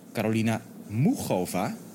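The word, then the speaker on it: Muchová: En het was Carolina Mugova, zo moeten we dat uitspreken.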